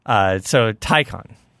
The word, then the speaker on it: Taycan: Taycan rhymes with icon. That's how you okay. – can remember it.